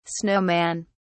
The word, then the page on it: snow man: British English